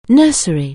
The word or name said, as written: nursery